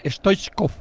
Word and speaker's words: Stoichkov: Estoy en directo aquí en la zona mixta con Stoichkov.